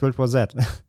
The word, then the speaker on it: World War Z: Ja, das heißt World War Z.